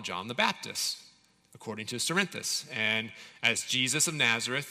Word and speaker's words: Cerinthus: there was a man named Cerinthus, or Carinthus. I'm pretty sure it's with a soft C, but Cerinthus.